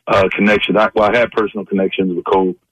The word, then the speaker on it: Kobe: And anybody close to him, we don't pronounce the E in his name. We just call him Kobe.